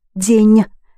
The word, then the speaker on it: день: День, N and soft sign in the ending.